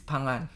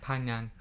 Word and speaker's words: Phangan: Yeah, whereas in English, or like even how Kai says it, it's Phangan. – Yeah, Phangan.